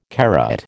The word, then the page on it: carat: American English